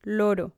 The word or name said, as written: loro